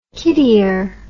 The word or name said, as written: kiddier